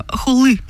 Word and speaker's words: Хулы: Там вот эта буква И на конце, во-первых, ударение, Тимур, не на У, как многие прочитают, да, а на последнюю. – Так я так и сказал. – Ну, и вот это И, она читается больше как И. то есть Хулы, как бы вот.